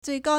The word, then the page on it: 最高: zuìgāo